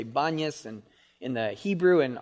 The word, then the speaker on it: Banias: And the reason why it's called Banias is because the Arabs cannot pronounce the letter P. So Panias, from the god Pan or Pan. But because the Arabs can't pronounce the letter P, they say Banias.